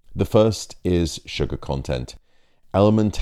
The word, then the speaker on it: LMNT: Today's podcast is supported by the electrolyte drink company Element. Now it's actually spelt with four letters, L-M-N-T, Element.